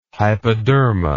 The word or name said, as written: hypoderma